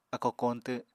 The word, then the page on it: akó koñtw: apatani